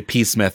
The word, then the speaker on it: Psmith: And I say P. Smith because if you aren't reading it, it is P-S-M-I-T-H, but the P is silent. – Very fine. – Just like in shrimp.